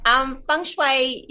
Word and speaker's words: feng shui: And so you're an accomplished author of your instruction book, Beginners Feng Shui. Are there opportunities within that book to learn the art of feng shui? And am I saying that correctly? – Okay. Feng shui, you actually pronounce it feng shui, and feng shui actually means feng shui.